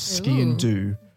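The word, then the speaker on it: sgian-dubh: It's called a ski and do, and the do is spelled D-U-B-H.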